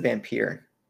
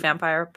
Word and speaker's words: Vampyr: I pronounced it Vampyr. – I'm pretty sure it's Vampyr, but... – But I would imagine if it's about vampires, the name would be read as such.